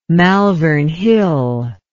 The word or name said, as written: malvern hill